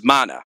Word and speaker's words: Mana: Her name is Mana. You know, it's pronounced just like Secret of Mana.